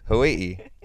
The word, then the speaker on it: Hawaii: Hawaii. That's how it's pronounced.